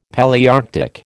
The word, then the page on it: Palaearctic: American English